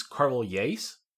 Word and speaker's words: Carvalhais: First of all, I think it's Carvalhais. – Carvalhais, okay. – Yes, I think it's with a J sound instead of an H sound.